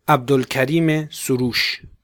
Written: Abdolkarim Soroush

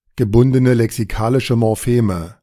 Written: gebundene lexikalische Morpheme

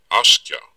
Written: Askja

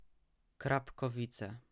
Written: Krapkowice

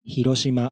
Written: Hiroshima

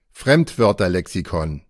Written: Fremdwörterlexikon

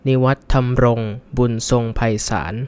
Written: Niwatthamrong Boonsongpaisan

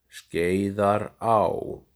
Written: Skeiðará

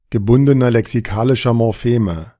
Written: gebundener lexikalischer Morpheme

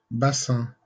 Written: Bassins

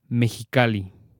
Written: Mexicali

